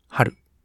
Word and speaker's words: 暖: これ漢字は、暖かいっていう漢字。あの、暖房とかの段ですね。暖を取るとかの段。って読み方がダン・ハルなんですって。